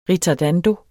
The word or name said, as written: ritardando